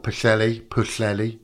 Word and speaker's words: Pwllheli: It's in a place called Pwllheli, that's how you say it.